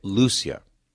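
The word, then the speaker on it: Lúcia: How do you say it? Lucia. Really? Lucia. I said, they don't say it that way in Spanish. Lucia. No, here we say Lucia.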